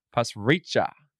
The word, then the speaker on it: Pasricha: Mr. Navdeep Pasricha. – Hey, I'm very impressed, that you pronounced that correctly.